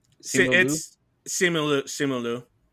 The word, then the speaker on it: Simu Liu: So this is the 25th movie in the Marvel Cinematic Universe, based on the Marvel character of the same name, starring Simu Liu. How do you say? Anybody know how to say his last name? – Simu Liu.